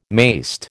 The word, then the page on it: mayst: American English